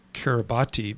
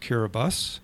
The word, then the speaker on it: Kiribati: And if people have seen the word, it looks like Kiribati, but I guess it's pronounced Kiribus. Is that correct? – That's right. Yeah, depending on the accent, Kiribus or Kiribus or Kiribati.